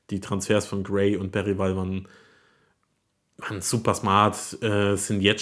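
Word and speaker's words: Bergvall: Und ja, also halt mit Gray und Bergwall und jetzt habe ich Bergwall gesagt. Das ist völlig falsch. Es ist ein Video kursiert, wo er seinen Namen ausspricht. Die richtige Pronunciation ist tatsächlich Lukas Bergwall.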